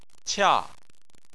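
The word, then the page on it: qia: tone 4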